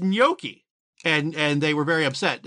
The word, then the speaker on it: gnocchi: So, the way I pronounce gnocchi, the way I always heard it, is that you have that Y sound in it.